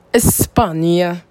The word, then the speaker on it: España: I love España. When I was learning Spanish in college, I used to love the pronunciation of Spain. The Spanish pronunciation. España.